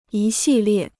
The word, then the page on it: 一系列: yī xì liè